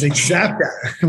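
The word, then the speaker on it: Axapta: That's how you pronounce that, Rebecca. It's Xapta with an X, right? It's like a Z.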